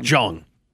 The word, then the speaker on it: Jeong: Is it, how do you pronounce Ken's last name? – Jong. – That's what I thought.